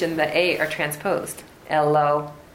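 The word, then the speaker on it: Eloah (אלוה): So just to pronounce this word correctly, I learned this from Rabbi Kahan, we might say Eloha, right? That would be the way we... – Yeah. – Right? But it's not. It's Eloah. – Eloah. – Eloah. Because there's a dot in the hay.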